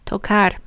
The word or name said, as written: tocar